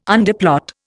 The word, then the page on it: underplot: Indian